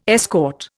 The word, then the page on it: escort: Indian